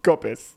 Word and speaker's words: Coppice: It can't be Coppice. – Literally, it's spelled Coppice. – Nah. – Surely Cops is Cops. – Well, I would go for Coppice. – What, you would? Okay. – Yeah. – Coppice. Correct.